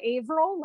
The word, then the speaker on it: Averell: So you can find me online at Amanda Averill. Last name is spelled A-V-E-R-E-L-L.